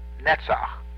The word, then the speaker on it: Netzach: And then we have Netzach. That's another guttural CH sound at the end.